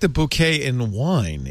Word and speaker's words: bokeh: Now, how do you pronounce it? B-O-K-E-H. – Japanese, the word is properly pronounced bouquet. – Okay. – Okay. – The only reason I say bokeh.